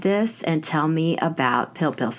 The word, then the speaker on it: pil pil: So the other night, I made your recipe for scallops and pil pil.